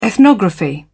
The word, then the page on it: ethnography: British English